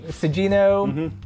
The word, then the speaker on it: Sugino: Just saying, Sugino is Sugino? – Sugino. – Not Sugino. – Not Sugino. It's Sugi, like the butter substitute.